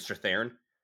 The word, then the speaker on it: Strathairn: I always mispronounce this guy's last name. David Strathairn.